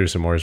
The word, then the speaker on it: s'mores: I've never heard someone say s'mores like you do. S'mores. I, su- I just say one syllable. S'mores. S'mores. S'mores. – What do I say? S'mores. S'mores. You uh, you you pronounce the apostrophe.